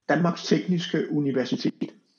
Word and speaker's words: Danmarks Tekniske Universitet: How do you say that? – Danmarks Tekniske Universitet.